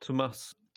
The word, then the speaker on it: Tomas: Thomas picked more. Is it Thomas? It's spelled Tomas. – Tomas.